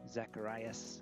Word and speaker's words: Zacharias: Is it Ravi Zacharias or Zachariah? – Zacharias, yeah, that's right.